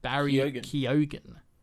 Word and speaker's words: Barry Keoghan: I finally figured out that's how you pronounce it. Barry Keoghan. According to Emerald Fennell.